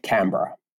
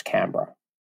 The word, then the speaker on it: Canberra: Pronounce the capital of your country. – Canberra. – Even though it's not spelled that way? – No, it's Canberra.